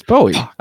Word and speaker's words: Bowie: It's Bowie. I thought you were saying Bowie on purpose. – I never know. It fucks me up. Well, because Bowie.